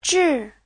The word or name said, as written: zhi4